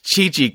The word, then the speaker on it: Chichi: I called Chichi Kiki for the longest time. – Oh yeah, and then was like, "Why are you pronouncing it like that?" – That's the one that I make fun with him all the time with. Every time I hear him say Kiki, I'm like, "Chichi," and like I don't care really. What was the Japanese pronunciation? Do you remember? Do they say Chichi? – I think so, actually.